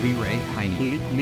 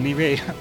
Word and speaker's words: Leray Heyne: Okay, tell me if I'm getting your name right. Liray Haynes? Leray Heyne. Lee Ray. Well, I got it totally wrong. – Lee Ray Heyne.